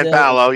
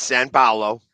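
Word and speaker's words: São Paulo: The Eagles will be the home team for the NFL's first ever game in Sao Paulo. I hope I pronounced that right. In Brazil. – San Paulo, yeah. Even though there's no N. It's San Paulo.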